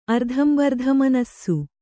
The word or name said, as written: ಅರ್ಧಂಬರ್ಧಮನಸ್ಸು